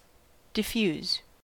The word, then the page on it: diffuse: American English